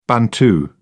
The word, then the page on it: bantu: British English